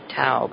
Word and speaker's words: Tao: And Tao is spelled T-A-O, in case you don't know. Some people pronounce that Tao, but it's Tao.